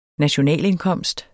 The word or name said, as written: NATIONALINDKOMST